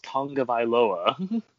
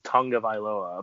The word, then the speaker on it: Tagovailoa: So that's my reason on why I don't think Tua, I'm going to say his last name correctly now, since I know how it actually is pronounced, Tagovailoa, and not Tagovailoa, it's Tagovailoa, apparently.